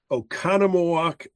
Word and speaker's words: Oconomowoc: And they instead found a place in Oconomowoc, Wisconsin.